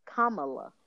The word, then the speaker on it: Kamala: You pronounce her name, comma, like the, uh, like a period, and then La, Kamala.